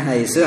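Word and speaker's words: Haisu (حيث): Haisu tidak bisa berubah jadi haisa atau haisi ya. Jadi meskipun gundul kita bisa baca. Bacanya gimana? Haisu. Walaupun didahului oleh min ya, min haisu ya kan. Wa yarzuqhu min haitsu la yahtasib. Jadi enggak ada kok min haisi.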